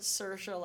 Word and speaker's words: Saoirse: Sorry, she pronounces it Saoirse. – Technically, it's Saoirse. She pronounces it Saoirse like inertia.